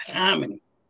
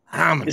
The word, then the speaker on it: Hominy: Say it right. See if you see. You should have just gave me the cue, and I would have said it's Hominy. – Hominy. – It's the hard I from.